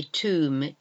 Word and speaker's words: tomb: Tomb, where you are buried in a tomb, T O M silent B, tomb.